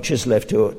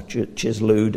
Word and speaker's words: Chislev: Well, from Chislev to Nisan, or Chislu, depending whether how you pronounce the Hebrew sometimes, Chislev or Nisan.